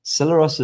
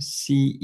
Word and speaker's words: Celeros: Celeros is spelled like celery, Celeros. Celeros.